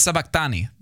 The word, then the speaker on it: sabachthani: Wait, no, it's sabachthani. That's how I said it. That's how it's... – Thank you. That's where the accent is on the Greek, so I was just trying to pronounce it.